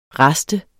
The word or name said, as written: raster